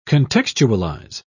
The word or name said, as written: contextualize